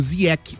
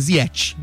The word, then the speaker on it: Ziyech: Então vou com o Brandão, Ziyech. – É que a pronúncia é marroquina, né? Ele disse isso na entrevista coletiva, mas como ele jogou muito tempo na Inglaterra, lá era Ziyech.